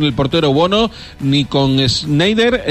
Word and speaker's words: Schneider: Eso te pasa por no confiar en tu pronunciación, que la habías dicho bien, Schneider.